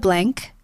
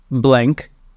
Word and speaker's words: BLNC: So it's called Blank. It's spelled BLNC. It's not launched yet. It won't be until next year. – Like Mont Blanc, like the pens, like Blank. – Yes, like, okay. BLNC. There's a reason why the A is missing. – Also, BLNC. – Yes. – So it's pronounced Blank, Blank.